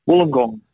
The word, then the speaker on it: Wollongong: Do I say that correctly? Wollongong. – Wollongong, yeah, that's correct.